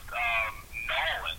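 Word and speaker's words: N'awlins: Well, actually, it's pronounced Nolan, so it's N apostrophe A-W-L-I-N-S. That's the correct way to do it, Nolans.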